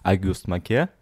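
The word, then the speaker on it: Auguste Maquet: А, подспорщик, который ему помогал писать, которого звали Агюст Маке, внимание на мое французское произношение,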